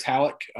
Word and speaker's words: Halicke: Yeah, you can follow me at Chris Halleck. Last name is spelled H-A-L-I-C-K-E.